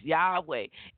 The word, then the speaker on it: Yahweh: The Hebrew pronunciation, as I said before, is Yahweh, and it's built on the a word or phrase, I am.